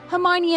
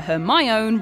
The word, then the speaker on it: Hermione: He calls her Hermione rather than Hermione, so Hermione decides to teach him the correct way.